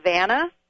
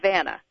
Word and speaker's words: Vanna: Well, what I like most, growing up with an unusual name, Vanna, I was always called something but Vanna. Vanna, Vanya, Vanna.